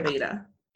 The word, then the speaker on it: Ayurveda: It's, we usually say I, like your I, okay. Ayurveda. – Ayurveda. Okay. Ayurveda. – Like that's how you would pronounce it.